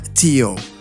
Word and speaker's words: Teooh: se llama Teo. Se escribe T-E-O-O-H.